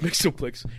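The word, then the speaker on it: Mxyzptlk: Yeah, it's got like a million letters, and it's it is Mixoplex. – It's pronounced Mixoplex.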